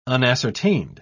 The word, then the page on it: unascertained: British English